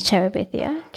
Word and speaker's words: Terabithia: LOUD10, as in L-O-U-D, 10. So we've got Cloud Street, Bridge to Terabithia.